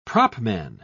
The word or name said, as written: propman